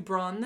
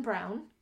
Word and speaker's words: Braun: Pronounced closer to Braun than Brown.